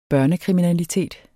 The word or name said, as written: børnekriminalitet